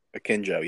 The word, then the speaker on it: Akinjo: I think Akinjo – that's how you pronounce it, right? – Akinjo, yes.